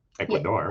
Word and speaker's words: Ecuador: You have to say it like that. Ecuador.